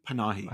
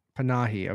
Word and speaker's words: Panahi: Panahi? – Panahi. – How do you say it? Panahi. Okay. I got it.